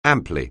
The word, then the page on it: amply: anglais